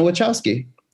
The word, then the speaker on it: Wachowski: Now, that's interesting because until 17 seconds ago, always thought that it was Wachowski. – A lot of people do. – And would hear people say Wachowski and be like, they're saying it wrong. – No, it's Lily and Lana Wachowski.